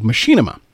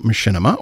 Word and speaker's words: Machinima: Machinima. And if you're not familiar with Machinima, or Machinima, depending on how you say it, this is a technique in which you use a real-time 3D engine, often from a video game, to create animated films.